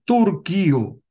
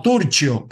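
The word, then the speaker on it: Turchio: Ahí tienen la foto del almirante Carlos Agnasi Turquío, o Turcio, pero en italiano se pronuncia Turquío, cuando fue nombrado comandante general de la Armada.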